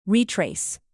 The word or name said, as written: retrace